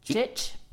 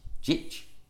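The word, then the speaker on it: Jikh: So he's called Andre, and I have literally no idea how to pronounce that surname, but it's J-I-K-H. – Jitch. – Jitch.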